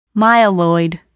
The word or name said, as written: myeloid